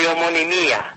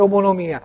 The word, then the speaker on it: omonimia: È un caso di omonimia. – Omonimia, bravo, l'hai detto bene, perché c'è chi dice anche omonomia.